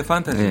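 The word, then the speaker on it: FNTSY: 소윤의 이 판타지라고 읽는 건가요? – 네, 판타지. 네. FNTSY라고 써 있는데 네.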